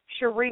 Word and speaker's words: Sharrie: You can find me on Facebook under the name Sheree Martin. That is spelled S-H-A-R-R-I-E, Martin, M-A-R-T-I-N.